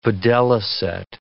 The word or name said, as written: videlicet